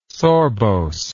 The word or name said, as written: sorbose